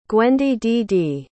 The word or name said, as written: gwendydd